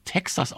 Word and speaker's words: Tejas: Wir wollen noch ein bisschen Musik hören von ZZ Top und ich habe aufliegen die LP Tejas, die glaube ich auch Texas ausgesprochen wird.